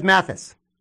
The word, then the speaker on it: Mathis: I mean, it's also, too, how do you think Posh Alexander, I believe the Rutgers transfer, his last name, Mathias, did I pronounce it right? – Montez Mathis.